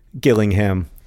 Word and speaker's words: Gillingham: Rosianna has just texted me to tell me that there is a third Gillingham. With a hard, this one is also with a hard G.